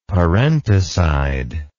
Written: parenticide

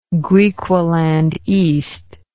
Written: Griqualand East

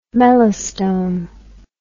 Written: melastome

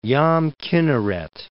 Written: Yam Kinneret

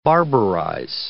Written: barbarize

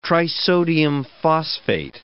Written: trisodium phosphate

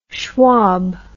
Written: schwab

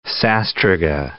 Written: sastruga

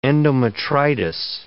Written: endometritis

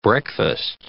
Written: breakfast

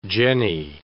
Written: jenny

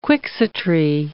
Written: quixotry

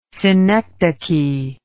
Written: synecdoche

